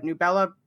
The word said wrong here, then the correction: Nebula: Are you sure it's not pronounced Nebula Awards? – Whatever.